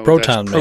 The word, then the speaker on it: Proton Mail: It's called Pronto Mail, P-R-O-N-T-O Mail. – Proton. No, Proton Mail. – Proton Mail. – Proton Mail. Sorry.